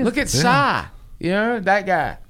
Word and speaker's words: Psy: That's how you say it? – Yeah, the ones... Well, at first, I thought it was short for pussy. But it's PSI, like psychology, I guess.